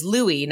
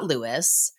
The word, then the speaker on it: Louis: Lo and behold, the entire time, I've been pronouncing your first name wrong. It is Louis, not Lewis at some point in time, I will go back and probably change things because that's just how my brain works.